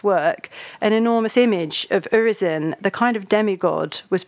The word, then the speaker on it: Urizen: There's a wonderful anecdote about St. Paul's Cathedral that you relate in the book, where one of Blake's pantheon, I want to pronounce it Urizen, because it sounds a little like horizon and that sense of kind of limitation,